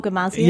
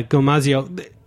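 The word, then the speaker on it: gomasio: There's a, how do you say, G-O-M-A, the... – Oh, gomazio. – Yeah, gomazio.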